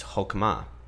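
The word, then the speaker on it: hokmah (חכמה): hokmah and you kind of have to like clear your throat in that second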